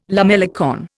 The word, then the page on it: lamellicorn: Indian